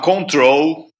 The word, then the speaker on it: CTRL: Isadora, o meu terceiro lugar meu, uh, o bottom do meu pódio vai para música nova da MIA, que chama Control, que na verdade se escreve CTRL, né? Uhum. O Control abreviado, só com as consoantes.